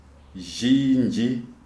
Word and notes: Zhinji: Shona

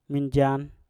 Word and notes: minjan: Mpur

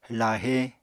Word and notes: lahe: Apatani

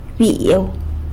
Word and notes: byiu: sanlucasquiavini

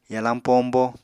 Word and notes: yalañ pombo: Apatani

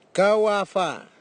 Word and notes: giwava: Nafe; Tanna

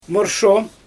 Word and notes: morsho: Xyzyl

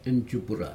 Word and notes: injupura: Aneityum

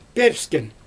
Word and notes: pIwpsken: Xyzyl

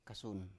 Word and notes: kasun: Biak

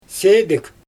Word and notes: seedIg: Xyzyl

